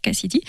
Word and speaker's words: Kcidy: Alors ça s'écrit K-C-I-D-Y, Cassidy,